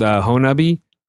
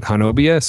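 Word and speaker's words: Honobia: There's a town in Oklahoma called Honubby. But if you look at it, it's spelled like Hanobia. So you can always tell someone who doesn't know what they're talking about, because they'll talk about Hanobia and nobody calls it Hanobia.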